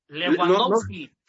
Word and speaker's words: Lewandowski: Oye, es un buen partido contra Lewandowski. – ¿Lewandowski? ¿Quién es ese? – Lo dije bien, Legua. – Levan. – En polaco así se dice, no, Legua. – No, Lewandowski.